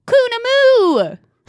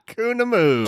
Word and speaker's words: Koonoomoo: Um, so I might pronounce it wrong, but I'm pretty sure it's called Koonoomoo. Koonoomoo.